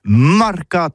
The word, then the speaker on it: marcat: Eh bien, disons à tous, mais le mercat de l'Uni ou le mercat des rebelles, mais en gascogne toulousano, c'est prononcé les marcat avec un A.